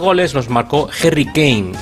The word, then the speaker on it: Kane: Kane, muy bien.